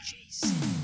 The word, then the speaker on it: Jacen: I've never heard of a Jason. It seems like not a Star Wars name. – I know, but it's spelled funny. It's spelled science fiction-y, right? J-A-C-E-N. – Jason.